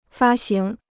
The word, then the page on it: 发行: fā xíng